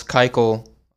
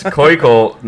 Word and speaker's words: Keuchel: Seeing these Cardinals Keuchel articles. – It's Keuchel, not Keuchel, you fucking Nazi. Actually, that is how you pronounce his name.